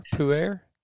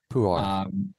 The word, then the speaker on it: pu'er: pu'er, pu'er? – Yeah, in the traditional Mandarin pronunciation, we call it pu'er, Pu'er. – but I hear it most often as pu'er in the West.